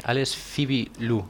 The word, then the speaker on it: Alice Phoebe Lou: Y escuchábamos Nostalgia, de la intimista artista Alice Foeb Lowe, no sé si lo estoy pronunciando bien, tampoco sé si lo estoy pronunciando del todo correctamente. Creo que es eh, Phoebe, Alice Phoebe Lou.